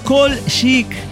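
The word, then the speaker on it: Kolshik: Es Kolchik.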